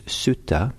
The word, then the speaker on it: sutta: Discourse, is the ancient word is sutta. S-U-T-T-A, and it's called the Chanki Sutta, and it's spelled usually in English C-A-N-K-I, and it's number 95 in the Middle Length Discourses.